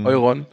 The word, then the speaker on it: Euron: Und aber noch ein anderer wichtiger Mensch steht da, nämlich Euron oder Euron oder wie wird er ausgesprochen richtig? – Euron. – Also auf Deutsch.